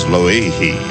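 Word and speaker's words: Loihi (Lōʻihi): Aloha, Art, the proper pronunciation of our new island here in the islands, is Loihi. That's as close as I can get anyway.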